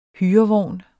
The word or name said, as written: hyrevogn